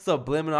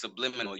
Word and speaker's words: Sublimental: Look, it's for everybody who doesn't know, it's spelled Sublimental with like, with Mental, but it's subliminal. You pronounce that shit subliminal.